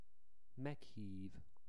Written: meghiv

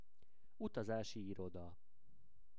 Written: utazasi iroda

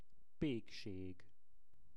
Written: pekseg

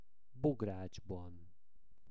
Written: bogracsban

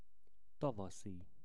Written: tavaszi

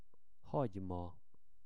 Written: hagyma

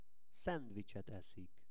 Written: szendvicset eszik

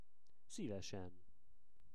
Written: Szivesen

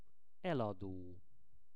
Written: elado

